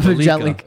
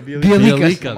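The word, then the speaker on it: Bjelica: It's not Bajelica. It's not Bielica. – Bielica. – There you go.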